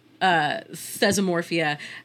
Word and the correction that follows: Thesmophoria: was included. – Thesmophoria. – Thank you.